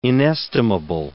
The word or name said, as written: inestimable